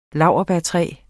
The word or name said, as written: NOBILIS